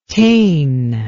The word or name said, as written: tain